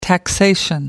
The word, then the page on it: taxation: American English